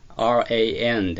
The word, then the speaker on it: ran: ran、この動詞ですが、これは run の過去形の ran で ran になりますね。この ran の a の音です。こちらをですね、まあ、発音記号で言うと A と E をくっつけたような発音記号なんですけど、これは後への、音のちょうど中間のような形ですね。あの口で A というふうな発音をするようにしてください。